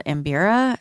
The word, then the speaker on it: mbira: It's also, that instrument is also called Mbira, is how I'm going to pronounce it, M-B-I-R-A.